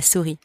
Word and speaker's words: souris: Don't pronounce the S at the end. La souris.